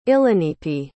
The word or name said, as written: illanipi